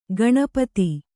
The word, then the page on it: ಗಣಪದ: gaṇa pada